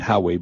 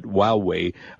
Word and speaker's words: Huawei: I think I finally pronounced the name right, not Huawei, but Huawei. Uh, their new phone, and they have their Mate 10 phone.